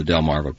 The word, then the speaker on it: Delmarva: But here in Maryland, as we mentioned before, in the Delmarva Peninsula, this is where the geese are in the winter.